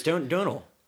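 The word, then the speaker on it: Domhnall: Yeah, no, it's Donal. – I don't know. – It's like the tonal. I've read about this. – It's like tonal. – You say tonal, but it's donal.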